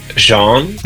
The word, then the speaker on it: Jean: Because it's spelled J-E-A-N. – Is pronounced differently every time because I pronounce it Jean, and a lot of people say Jean, so I'm used to having like Patrick Jean or whatever.